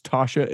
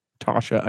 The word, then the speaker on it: Tausha: Both Michael and his wife, that I believe you pronounce Tasha. It's sort of that Utah spelling of things. T-A-U-S-H-A. Tasha, I'm pretty sure.